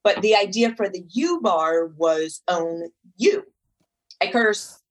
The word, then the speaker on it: UBARRE: So yeah, the U bar, it's spelled UBARRE, like in posture, and then it would be plural, would be U bars.